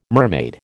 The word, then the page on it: mermaid: American English